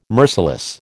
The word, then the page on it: merciless: American English